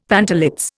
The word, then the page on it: pantalets: Indian